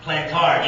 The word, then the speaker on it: Plantard: Plantard, yeah.